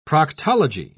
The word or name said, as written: proctology